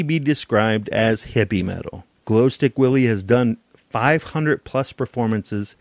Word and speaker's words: Glostik Willy: And I came across a band called Glow Stick Willie. I will spell that for you. It's G-L-O-S-T-I-K. And then Willie is W-I-L-L-Y.